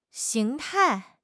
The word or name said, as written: 形态